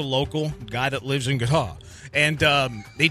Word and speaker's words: Qatar: The official pronunciation of my country is Qatar.